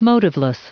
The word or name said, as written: motiveless